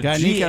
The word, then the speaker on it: Garnica: Garnica was, am I pronouncing that right? – Garnica, that works. – G-A-R-N-I-C-A.